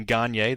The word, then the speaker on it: Gagne: If you prefer to pronounce my surname Gagne, that is also acceptable, but spelled the same way.